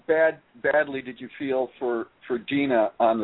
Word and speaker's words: Deana: And for those of you that haven't watched it, she spells her name Deanna. D-E-A-N-A, but she keeps saying, no, I pronounce it Dina.